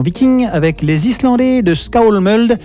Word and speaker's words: Skálmöld: Enfin bon, littéralement, c'est Skálmöld, hein, mais bon, il y a un accent sur le A, donc c'est A-O, et il y a un tréma sur le O, donc c'est E, donc je prononcerai Skálmöld.